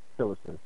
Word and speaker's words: Tillerson: So yeah, all of this is a big part, and I'm wondering if Rex Tillerson, if I'm saying his name correctly, Tillerson. Yeah.